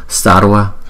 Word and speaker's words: Sarva: Bhagavate Bhagavate Bhagavate Bhagavate Sarva Sarva Sarva, có chữ R chính giữa nhé.